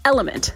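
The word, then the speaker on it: LMNT: It's L-M-N-T, pronounced Element.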